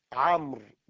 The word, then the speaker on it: عمرو: كلمة عمرو بسكون الميم، لو كتبناها بالرسم الإملائي نكتب عينا ميما راء واوا. إذا كلمة عمرو بعدها واو، بعد الراء واو، وكلمة عمر لا واو فيها.